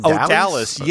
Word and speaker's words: Odalys: Is that how you pronounce Odalis? – Odalis, yes.